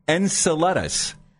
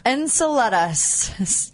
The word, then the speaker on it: Enceladus: It's pronounced Enceladus. Enceladus? – Enceladus.